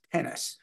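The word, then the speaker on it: Tenace: There was a player in the early 70s through the early 80s named Gene Tennis, not spelled like the sport, but spelled like the word 10 and the word ace, tennis.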